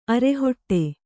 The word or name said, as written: ಅರೆಹೊಡೆ